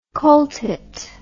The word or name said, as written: cole tit